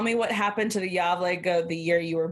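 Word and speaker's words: Gävle: It's spelled G-A-V-L-E, but the A has a bunch of little funky stuff over it. So I went to YouTube and found out that it's pronounced Yavle. Yavle.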